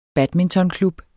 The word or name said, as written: badmintonklub